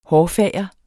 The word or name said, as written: hårfager